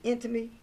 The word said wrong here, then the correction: enmity: Intimacy, E-N-M-I-T-Y. It's intimacy. – Enmity. – Enmity.